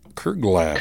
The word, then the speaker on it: Kerglaff: It is a Kerglaff? I think Kerglaff? I need to look this. How do you say this? – Kerglaff. – Kerglaff. – Kerglaff. That is Kerglaff. Correct.